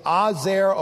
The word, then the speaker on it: ezer: The Hebrew word for help is the word ezer. It's spelled E-Z-E-R, or you could say Azer or Ezer.